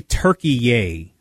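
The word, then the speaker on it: Türkiye: And then I'm going to just pronounce it Turkey. I know you've probably got the proper pronunciation. – They now say Turkey-ay. So it's Turkey with an A on it. Turkey-ay.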